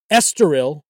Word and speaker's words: Estoril: Estoril, hope I said that correctly.